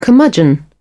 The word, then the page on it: curmudgeon: British English